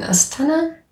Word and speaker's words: Astana: Und wenn du jetzt so zurückdenkst an deine Zeit in Astana, wie spricht man das aus? Astana.